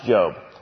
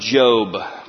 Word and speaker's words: Job: So we've been studying a man by the name of Job, but it's spelled Job, which is kind of weird.